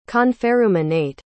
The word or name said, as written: conferruminate